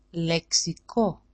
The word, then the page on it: leksiko: leksiko-